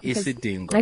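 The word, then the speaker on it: Isidingo: Isitingo. – Thank you. – Oh, thank you. I just can't pronounce it. – Yeah, Isitingo. – Isitingo. – Isitingo. – I think of the dog, Dingo. Isitingo.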